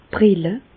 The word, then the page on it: d' Brille: Bas-Rhin; Reichshoffen